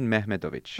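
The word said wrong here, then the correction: Mehmedinović: Okay. – Yeah, Mehmedinovic, actually.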